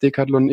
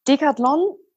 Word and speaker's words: Decathlon: was Decathlon ist, wo es herkommt und was es alles für Sportarten vielleicht auch abdeckt? – Klar, gerne. Also Decathlon oder Decathlon ähm, auf Französisch ausgesprochen.